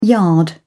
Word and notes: yard: British English

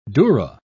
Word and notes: durra: British English

